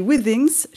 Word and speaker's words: Withings: Alors vous êtes chef de projet innovation santé chez Withings.